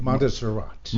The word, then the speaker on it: Montserrat: Montserrat.